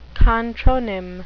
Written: contronym

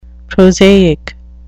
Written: prosaic

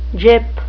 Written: gyp

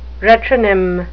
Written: retronym

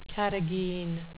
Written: carrageen

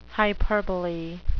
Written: hyperbole

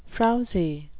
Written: frowzy

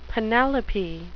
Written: penelope

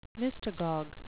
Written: mystagogue

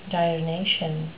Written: diurnation

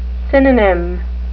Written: synonym

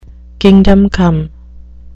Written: kingdom come

